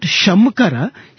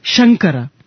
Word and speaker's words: Shankara: Okay, it is not Shamkara, Shankara.